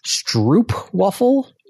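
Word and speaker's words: stroopwafel: And I set the stoop waffle. – Isn't there an R in there? – I think it's Dutch, so I'm not sure if the R is pronounced.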